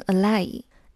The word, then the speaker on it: ally: 要注意它的发音，重音在第一个字母前面。 ally 千万不要读成 ally。